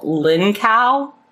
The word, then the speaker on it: Lincow: But her name is Gail Lincow. Am I saying that right? L-I-N-C-O-W? – I would say it like that, yes.